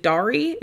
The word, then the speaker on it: Dari: Is it Dari? It's D-A-R-I. – I think it's Dari, if I'm not mistaken. But again, this is me being Iranian. Yeah. I'm sure in Afghanistan, they pronounce it differently.